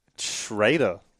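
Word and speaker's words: Schröder: You're going to pronounce that last name for me. – Schrader. Dennis Schrader. – Dennis Schrader. – So you've got Griffin. – It's the German.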